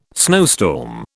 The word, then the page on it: snowstorm: British English